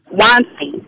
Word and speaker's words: Weinstein: And then people began to bring in Harvey Weinstein, if I'm pronouncing his last name correctly. Say it again, I'm sorry. – Weinstein. Yeah.